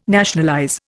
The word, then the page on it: nationalize: Indian